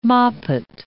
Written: moppet

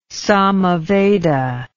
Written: samaveda

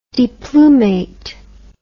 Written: deplumate

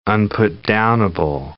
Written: unputdownable

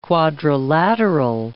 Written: quadrilateral

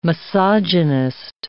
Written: misogynist

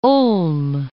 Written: Ulm

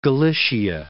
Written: Galicia

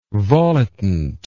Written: volitant

